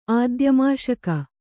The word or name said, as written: ಆದ್ಯಮಾಷಕ